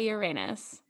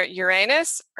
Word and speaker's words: Uranus: U-R-A-N-U-S. – I say Uranus. – It's either Uranus or Uranus, right? – Right. – So you get urine or anus.